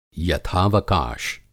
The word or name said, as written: यथावकाश